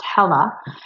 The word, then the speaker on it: Hela: Now, Kyrie was given a Lakota name, and it's pronounced Hela, and it means little mountain.